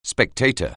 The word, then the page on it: spectator: anglais